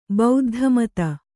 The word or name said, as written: ಬೌದ್ಧಮತ